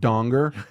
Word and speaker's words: Danger: And he goes, actually, it's pronounced Donger.